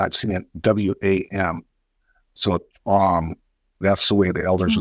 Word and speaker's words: C'waam: C'waam. – C'waam. Am I saying that right? – Yeah. It's like a T and C together. C'waam.